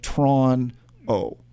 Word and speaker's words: Toronto: It's just what, but people tend to say, I was like, being from Toronto, they say Toronto, not Toronto. You know, like we only use two syllables. – Toronto. – It's like you run it to. – It's two syllables. They shorten it up. It's Toronto. – Yeah. – It's like Tron-O. That's basically it.